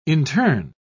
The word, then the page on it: interne: British English